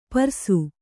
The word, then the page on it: ಪರಸು: Kannada